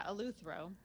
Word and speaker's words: Eleuthero: Um, Eleuthero? – Mm, mm-hmm. – Is that how you say it? Eleuthero. – Yeah, Eleuthero. Okay.